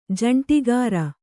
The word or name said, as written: ಜಂಟಿಗಾರ